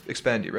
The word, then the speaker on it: Expandi: Expandy, right? – Correct.